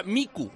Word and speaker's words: mico: Otro dice, yo soy catalán y si hubiera utilizado la palabra mico, que significa mono en catalán, se pronuncia micu, así que se notaría la diferencia al acabar.